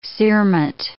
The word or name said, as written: cerement